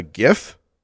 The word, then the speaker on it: GIF: It's a GIF and Imgur. hard hard G's in this house.